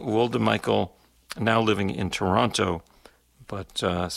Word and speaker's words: Woldemichael: From there, we're going to close this set off with music from the Ethiopian sax player, Girma, G-I-R-M-A, I think I might be pronouncing that right. Last name, Woldmichael, W-O-L-D-E-M-I-C-H-A-E-L, Woldmichael.